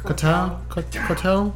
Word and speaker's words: Koh Tao: Am I saying that right? Katao? – That sounds right.